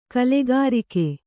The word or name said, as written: ಕಲಿಕಾರಕ